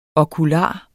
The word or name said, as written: okular